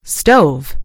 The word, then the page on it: stove: American English